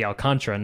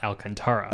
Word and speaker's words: Alcantara: Sandy Alcantara is Sandy Alcantara, not Alcantara.